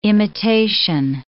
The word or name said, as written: imitation